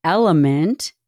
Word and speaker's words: LMNT: That's pronounced Element, spelled L-M-N-T.